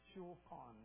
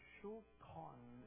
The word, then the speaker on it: shulchan: It's actually spelled S-U-L-H-A-N, but it's said S-H-U-L-H-A-N. C-H-A-N, shulchan. That's how it's, because if you're Hebrew, you got to go. So, shulchan is how it's said. Food or the meal is shul, S-U-L, but it's shul, S-H-U-L, shul.